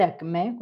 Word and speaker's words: ACME: Bueno, ACME básicamente, bueno, el significado de ACME con acento en la E,